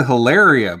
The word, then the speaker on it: Hilaria: Married to Hilaria Baldwin. Yeah. The uh, is, that how you say her name? Yeah.